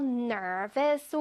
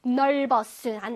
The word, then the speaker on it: nervous: 넓었은 안 돼. 넓었은 no. 시작. Nervous. Nervous. R이니까 혓바닥 뒤로. Nervous. 이거 조금 어려워하더라고, 우리 친구들 발음. 다시 시작. Nervous. Nervous. V는 요거, 아랫입술을 이빨로 살짝 이렇게 물어주세요.